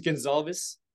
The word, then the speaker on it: Gonsalves: But my last name's Gonsalves, or, you know, but they got mistaken with the Spanish version, or in Portuguese, it's Gonsalves.